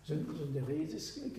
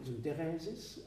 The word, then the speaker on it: Synderesis: Synderesis, griechisch betont man Synderesis, aber im Lateinischen Synderesis oder Syntheresis, auch das gibt es mit D oder mit T.